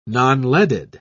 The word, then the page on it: nonleaded: British English